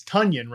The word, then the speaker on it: Tonyan: Like I, I'm I, looked Tonyan he's, he's Tunyon, right? Tunyon the Funyon uh, uh, is how you actually officially pronounce his name.